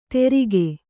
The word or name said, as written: ತೆರಿಗೆ